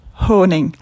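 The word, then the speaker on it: Haaning: And without sounding like too much of an ass, I just wanted to let you know the proper pronunciation of the name of the artist. His name is Jens Honing. So the last name is Honing.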